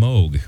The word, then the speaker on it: Moog: Pronounced his name Moog.